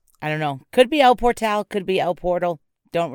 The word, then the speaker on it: El Portal: That's not pronounced El Portal. That's El Portal.